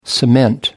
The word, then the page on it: cement: American English